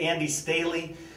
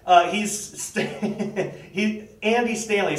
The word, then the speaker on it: Stanley: But so North Point Community Church senior pastor Andy Stanley, I swear I'm going to keep saying that wrong. I keep wanting to say Andy Staley. But anyway, Andy Stanley.